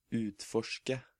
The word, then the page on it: utforske: Norwegian